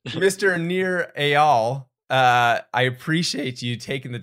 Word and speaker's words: Nir Eyal: Nir Eyal.